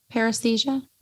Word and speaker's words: Paresthesia: Sure. Pronounced. Paresthesia. – I mean, that's what I went with because that's what you said. – That sounds right to me.